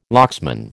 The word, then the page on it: locksman: American English